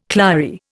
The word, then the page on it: clary: Indian